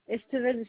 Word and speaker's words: Estevez: Estevel Céline. – Comment ça s'écrit, s'il vous plaît? – Alors, ça s'écrit E-S-T-E-V-E-Z.